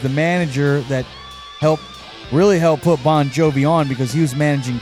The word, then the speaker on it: Bongiovi: They spell it J-O-N when his real name is actually spelled J-O-H-N. And he goes by John Bon Jovi with a B-O-N-J-O-V-I. The real spelling of his last name is like bong, like smoking a bong, B-O-N-G-I-O-V-I, but it has the same pronunciation. Bon Jovi with the G-I-O-V-I.